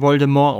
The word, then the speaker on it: Voldemort: Also Harry kriegt ja sogar jetzt schon die Info, dass dass Voldemort Laut J.K. Rowling wird das übrigens Voldemort ausgesprochen.